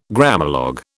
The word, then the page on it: Grammalogue: British English